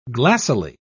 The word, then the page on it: glassily: British English